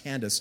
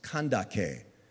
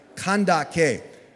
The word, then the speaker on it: Kandake: And actually, it wasn't pronounced Candace. It was pronounced with hard C's, Kandake. Can you say that out loud with me? Say it. Kandake.